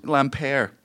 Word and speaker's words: Lampere: I can't believe fucking Lambert fucked him over so bad. – You still get his name wrong. – Yeah. – Why am I saying that wrong? – Lampere. – Oh, God. – It's French.